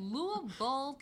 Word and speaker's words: Louisville: Louisville. Like, one of the first things I had learned was you got to say the name right. You you got to swallow the word when yes.